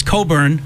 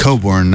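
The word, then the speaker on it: Cockburn: Bruce Coburn, that's how it's pronounced. – Coburn, not the other way.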